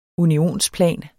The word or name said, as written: unionsplan